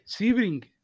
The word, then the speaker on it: Sebring: Entonces, ¿cómo se dice? – Sebring, Sebring, vas a Sebring, Florida.